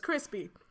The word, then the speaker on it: Chrispy: Okay, I am, my name is Chrispy, that's spelled C-H-R-I-S-P-Y, also known as Lando Cal Crispian, the ad lib queen and sloppy toppy goddess.